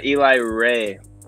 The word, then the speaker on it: Eli Reagh: Yeah, Eli Reagh.